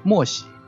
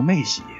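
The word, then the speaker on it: 妺喜: 第五点呢，那他俩身边都有一个缺心眼的美女，夏桀身边的美女叫莫喜。一般我们都念成妹喜啊，有首诗用来形容妹喜长得有多好看。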